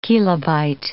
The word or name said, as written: kilobyte